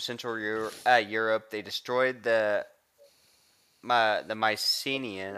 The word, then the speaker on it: Mycenaean: The agriculture of the how do we say it? Mycenaean Mycelian? – No.